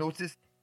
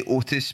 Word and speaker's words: Otis: I'm trying to say Otis because I say Otis and it's apparently not that nice. Funny story. – Otis. It's a very Scottish. It's a Scottish thing to not pronounce a T in it.